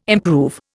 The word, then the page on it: improve: Indian